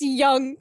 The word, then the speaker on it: Jung: It's J-U-N-G. – Europe. – Oh. – I made that same mistake the first time. – It says J-U-N-G typology. – But it's Young, because it's Europe.